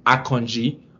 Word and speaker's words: Akanji: The way you pronounce his name, because he's Nigerian and I have to call it out, and he's playing for Switzerland, is Akanji.